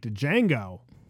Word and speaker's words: Django: It was like Django. – Wow. – It was like Django. – It's like, the D is silent, hillbilly.